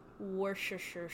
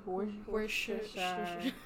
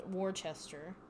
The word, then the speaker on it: Worcestershire: I think I know somebody who pronounced it as Worcester one time, and I was like, no, no, definitely not.